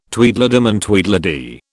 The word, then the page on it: Tweedledum and Tweedledee: British English